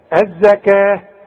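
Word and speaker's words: الزكاة: الزكاه، كل الزكاه بتاعه القران مكتوبه بالواو لكن تنطق بالالف، الزكاه، ورسم لك الف اهي، ثم توليتم.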